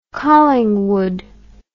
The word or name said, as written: Collingwood